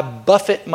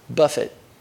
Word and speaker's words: buffet: He said, "But I buffet my body." Now notice the pronunciation of that word, men. Buffet, not buffet.